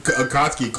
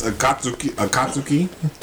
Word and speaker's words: Akatsuki: Kotsky? – Akotsky. – Akotsky. – Akotsky. – And it doesn't look like how it's spelled. That's the point. Akotsky? – Because it's Akatsuki.